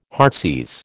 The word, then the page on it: heartsease: American English